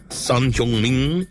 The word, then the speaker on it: Son Heung-min: If you pronounce it to the T, Son Heung-min, right?